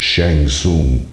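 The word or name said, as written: Shang Tsung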